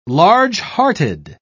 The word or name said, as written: largehearted